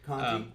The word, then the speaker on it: Conti: So we get our first real taste of Bill Conti. Is that how you say his name? – Yeah, Conti. I think Conti.